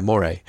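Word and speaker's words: moiré: But when you're manually focusing, you can sometimes get what appears to be is it Moire? Moire? I can never quite remember how you pronounce it. Yeah, Moire.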